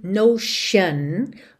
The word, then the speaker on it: notion: e atenção à pronúncia de notion, ok? Frequentemente palavras terminadas em T-I-O-N, esse T tem esse som de X ou S-H, shun, shun, ok?